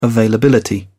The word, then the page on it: availability: British English